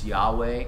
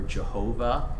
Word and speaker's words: Yahweh: And the one that's it's most commonly pronounced is Yahweh or Jehovah, though Jews don't pronounce the name because it is the most holy name.